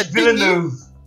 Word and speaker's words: Villeneuve: Eu, eu não sei se estou pronunciando o nome dele certo. – Denis é Villeneuve. – Denis Villeneuve. Villeneuve.